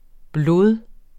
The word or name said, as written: blod